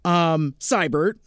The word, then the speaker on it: Seibertron: So as far as I know, there are two acceptable pronunciations for that name. My way, Seibert, or Seibert, like my name brother Matt Seibert from the Mr. BS show out of Colorado, who spells it exactly the same way that I do, and now the same way as this Heritage Transformers website.